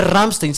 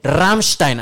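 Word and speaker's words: Rammstein: No se dice Ramstein, se dice Ramstein.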